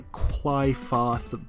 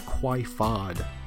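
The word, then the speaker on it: Qliphoth: It's spelled like Clyphoth, but I think the characters say it like Quifod.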